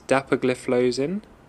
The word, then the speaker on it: dapagliflozin: The first is dapagliflozin, the second is canagliflozin, and the third is empagliflozin.